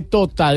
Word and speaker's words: Tota: Es más, lo dejo, es porque ahora voy para la laguna de Teta. De Tota, de Tota, tota doctor.